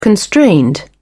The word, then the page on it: constrained: British English